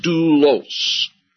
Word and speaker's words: doulos: D-O-U, and then the next letter looks like an upside-down Y. That is an L or a lambda. O-S, and that's pronounced doulos.